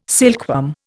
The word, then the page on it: silkworm: Indian